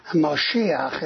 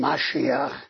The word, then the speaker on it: Mashiach: Moshiach is an Ashkenazi pronunciation. Mashiach is the Sephardic.